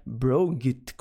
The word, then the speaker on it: brought: Muito interessante, porque é difícil de olhar para essa palavra e acertar a pronúncia de primeira. É brought.